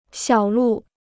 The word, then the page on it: 小路: xiǎo lù